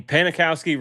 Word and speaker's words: Panikowski: And I mean, Panikowski rhymes with Janikowski.